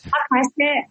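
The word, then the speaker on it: Khakassia: This is the Republic of Kakassia. Do I say that correctly? And that's Kakassia. Yes, with us.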